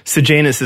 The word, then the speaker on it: Sejanus: Sejanus is how they pronounced it in the audiobook, but again.